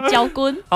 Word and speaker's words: 昭君: 昭君的台语怎么念？焦君。